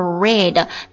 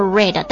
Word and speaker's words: read: 它的原型是 read 读音是 read 但是它的过去式读作 read 那过去分词呢也读作 read